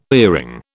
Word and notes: earring: British English

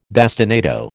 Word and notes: bastinado: American English

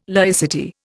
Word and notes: laicity: Indian

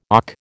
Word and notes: OC: American English

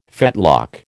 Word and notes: fetlock: American English

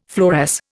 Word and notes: fluoresce: Indian

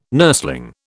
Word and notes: nursling: British English

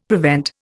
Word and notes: prevent: Indian

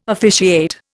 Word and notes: officiate: Indian

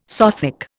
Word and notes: Sothic: Indian

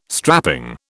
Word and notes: strapping: British English

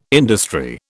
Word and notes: industry: British English

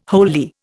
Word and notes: wholly: Indian

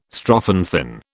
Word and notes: strophanthin: British English

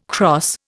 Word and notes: cross: Indian

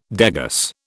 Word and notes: degas: British English

